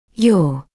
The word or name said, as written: your